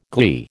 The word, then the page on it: glee: American English